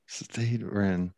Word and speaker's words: Stade Rennes: And Stade Rennes. How do you say it? – Stade Rennes.